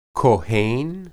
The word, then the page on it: kohen: hebrew